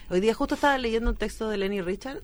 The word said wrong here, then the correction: Nelly Richard: de Nelly Richard, ahí lo dije bien,